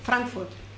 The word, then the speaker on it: Frankfurt am Main: Lo he dicho bien, buenas tardes. ¿Cómo se dice? – Frankfurt. – Frankfurt am Main.